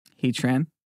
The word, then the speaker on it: Heatran: Heatran.